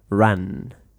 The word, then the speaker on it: ran: you have to pronounce, you know, the only difference between the A and the U, you know, run and ran, is that when you have an A, cuando tienes una A, you pronounce with your mouth really, really open. So you say ran.